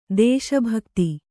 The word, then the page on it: ದೇಶಭಕ್ತಿ: dēśa bhakti